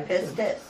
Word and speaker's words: pistis: The Greek word used, the way it's pronounced according to the Thayer and Strong's, is, I guess, pistis. I guess it's yeah, pistis.